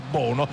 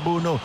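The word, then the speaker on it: Bounou: ha due pronunce Bunu, o Bunu o Bono così come ha scritto sulla maglietta, però insomma la pronuncia in lingua marocchina è Bunu.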